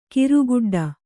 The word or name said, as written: ಕರಿಗಿಡ್ಡ